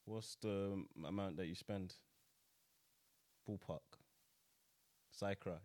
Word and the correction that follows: circa: is that how you pronounce it? – Circa. – Yeah.